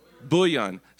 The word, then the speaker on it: bouillon: Bouillon. So the L is silent. Is that right? It's not bully, is it bouillon or bouillon? Bouillon.